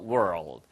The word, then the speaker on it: world: In the world. World 발음을 조금 어려워하시는 분들이 계신데요. Word는 단어란 뜻이고요. World까지는 똑같아요. 근데 L 한번 튕겨주세요. 혀 끝을 튕겨서 world. World 이런 식으로 발음하시면 이게 바로 세계라는 뜻입니다.